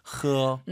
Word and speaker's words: 喝: 喝어, 이게 아니라 약간 긁으면서 발음하셔야 됩니다. 喝. 일부러 긁지 마시고요. – 네, 약간 긁는 소리가 났어요. 허.